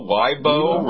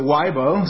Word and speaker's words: Weibo: It was a study that was in China, a network that was called Weibo, I guess. – Weibo, rhymes with Taibo, yeah. – Yeah, Taibo, so Weibo.